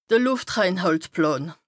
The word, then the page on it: de Lùftreinhàltplàn: Bas Rhin